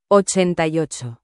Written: 88

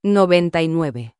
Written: 99

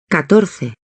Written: 14

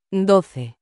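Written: doce

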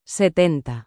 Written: 70

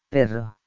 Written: perro

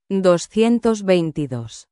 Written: 222

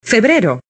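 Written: febrero

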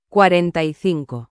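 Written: cuarenta y cinco

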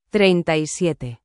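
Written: treinta y siete

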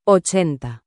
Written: 80

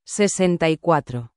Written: 64

